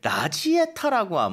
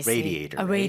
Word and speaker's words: radiator: 쌤은 아마 모르실 건데 한국에서 이렇게 라지에타라고 아마 자동차의 라지에타? 이렇게 발음을 알고 계시는 분들이 많을 거예요. 잘못 알고 있는 발음인 거예요. – Radiator.